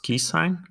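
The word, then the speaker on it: SQIsign: Yeah, so I believe it's pronounced key sign.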